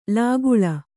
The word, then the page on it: ಲಕ್ಕಲಿ: Kannada